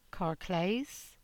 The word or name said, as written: Carclaze